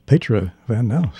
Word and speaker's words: Petra Van Nouse: Oh, I say, let there be Petra Van Nouse. Good sound. – Great pronunciation, by the way. – Oh, good. – Wow, you did it right.